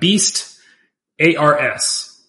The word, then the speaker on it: Beastars: It's spelt like Beast, A-R-S. Beastars.